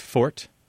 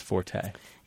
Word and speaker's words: forte: And I think you'll find that most very careful speakers will pronounce it as fort. And then, but most people in general pronounce it as forte. – Yeah, the vast majority, I think, say forte. – Yeah, most, I mean, we're talking like 99%. And actually, many people, if they hear it pronounced fort, are confused because they don't know what you mean.